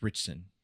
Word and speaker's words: Ritch: Alan Rich, how do you pronounce his last name? Alan Richardson? – Richardson? – I say Richardson. – It's Richardson. – Is it Ritson? From what? – R-I-T-C-H.